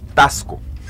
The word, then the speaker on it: Taxco: Y sobre todo, hoy, hoy eh, es importante recalcar que estamos aquí en, Taxco, Tasco. Es, una, se escribe con X, pero se... se, Tasco, sí, se pronuncia. se con S.